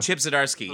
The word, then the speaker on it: Zdarsky: Chip Zdarsky. Yes. – Zdarsky and Mark Bagley.